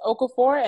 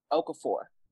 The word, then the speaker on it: Okafor: The last name is Okafor, and it's spelled O-K-A-F, as in Frank, O-R, Okafor.